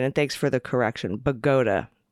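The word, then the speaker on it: Bogota: The town in New Jersey is pronounced Bagoda, like Abe Vigoda.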